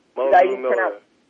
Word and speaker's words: Mulgrew Miller: Mulgrew Miller. – Mulgrew Miller.